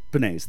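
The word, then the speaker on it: Pensées: Penis. – I almost just said penis. – That's yeah, well because it's literally spelled like penis with an extra E.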